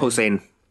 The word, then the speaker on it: Posehn: How do you pronounce his surname? Brian Posehn.